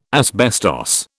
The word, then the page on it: asbestos: British English